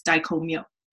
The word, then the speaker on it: Dai Ko Myo: And so I asked her, how is this actually pronounced? And she said, it's Dai Ko Myo.